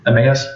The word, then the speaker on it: Emmaus: They did not believe the two men that were on the road to Emmaus, however you pronounce that, Emmaus, thank you.